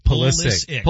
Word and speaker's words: Pulisic: I've heard everything from Pulisic to Pulisic. – It's Pulisic. – It's Pulisic. Yeah. – It's Pulisic. – Pulisic. Pulisic. – No. – Pulisic. – Pulisic.